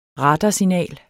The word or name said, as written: radarsignal